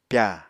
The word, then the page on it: pyà: Apatani